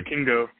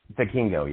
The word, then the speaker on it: Vikingo: And watching Viking, how do you pronounce it? Viking? – Yeah, Vikingo. – Vikingo, yeah. Vikingo.